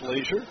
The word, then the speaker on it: Leashure: L-E-A-S-H-U-R-E, pronounced Leisure.